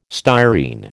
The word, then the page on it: styrene: American English